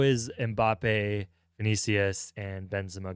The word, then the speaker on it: Vinicius: Vinicius Jr. Am I saying his name right, by the way? – Yeah.